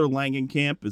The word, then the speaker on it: Langenkamp: And Heather Langenkamp, is that how you say her name? Heather. – Sounds right.